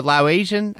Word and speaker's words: Laotian: Her parents are originally from Laos, I think they're Laotian. I think that's how you pronounce the name.